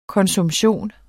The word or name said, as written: konsumption